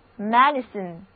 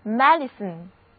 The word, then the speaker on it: medicine: 通常生病了就会吃药，这种可以吃下去的药叫做内服药，就是 Medicine M-E-D-I-C-I-N-E Medicine。字母 E 的读音是小口的 A，第一个字母 I 发短的 E，第二个字母 I 则不发音，大家一起来念念吧。 Medicine Medicine 内服药，医学的意思。